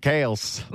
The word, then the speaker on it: Kelce: Well, my family pronounces it Kels.